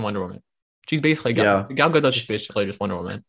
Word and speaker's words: Gadot: Gadot. – Gadot. I'm pretty sure that's how she pronounces it, because that's how she told Jimmy Kimmel it's pronounced, or Jimmy Fallon.